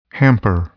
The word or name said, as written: hamper